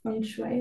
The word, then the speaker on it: feng shui: Okay, so at the beginning, we talked a little bit about feng shui. Is it the correct pronunciation? – Yes.